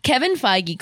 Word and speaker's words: Kevin Feige: Kevin Feige called, and we're going to take on the multiverse.